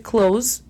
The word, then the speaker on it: close: Recordarás que close termina con E, ¿cierto? – Sí. – Gramáticamente. Pero cuando lo pronunciamos, close termina en S.